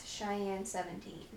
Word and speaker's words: Cheyenne: So instead of Cheyenne, the most common one that I've gotten is Sheehan. I don't know why they would say Sheehan, because she is S-H-E and my name is S-H-I, but that was a popular one.